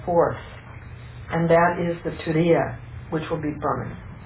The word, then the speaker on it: Turiya: The Turiya. Turiya means T-U-R, long I-Y-A.